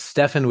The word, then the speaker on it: Stephan: And I also want to point out that Stefan is spelled S-T-E-P-H-A-N. – All right. So it's Stefan with a PH, not with an F. So S-T-E-P-H-A-N, Stefan Spencer, thank you very much for joining us.